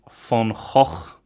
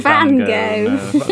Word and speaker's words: van Gogh: Then there's The Old Man Drinking Coffee by Vincent van Gogh, as I've been told it's pronounced. – But so it's not Van Gogh. – These Dutch people. It's not Van Gogh.